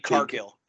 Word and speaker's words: Cargill: Cahill, is that how you say it? – Jade Cargill, yeah.